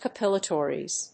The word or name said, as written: capillaritis